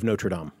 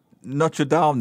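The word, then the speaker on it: Notre-Dame: And this week, one of the, I think, sadder stories when it comes to history, archaeology, architecture, the burning of Notre Dame. – I noticed you said Notre Dame then. I know Americans say Notre Dame, right? – Well, that's the football team.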